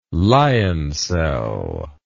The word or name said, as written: lioncel